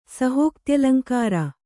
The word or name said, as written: ಸಹೋಕ್ತ್ಯಲಂಕಾರ